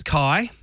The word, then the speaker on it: Keir: Kia? K-E-I-R? – No, it's Kai.